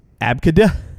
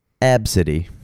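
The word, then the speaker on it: Abcde: How is it pronounced? – How would you pronounce it? – Abkada? – No. They turned it... – Probably Megan? – They turned it into a oddly pronounceable name, which she can go fuck herself, but Absidy.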